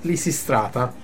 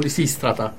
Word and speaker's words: Lisistrata: che si chiama Aspettando Lisistrata, o Lisistrata che dovrebbe essere, perché io ho l'accento spostato.